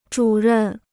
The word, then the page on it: 主任: zhǔ rèn